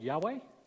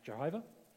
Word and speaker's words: YHWH: So you've heard of God called Yahweh, or sometimes it's pronounced Jehovah. The sacred name of God.